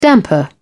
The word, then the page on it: damper: British English